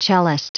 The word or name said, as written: cellist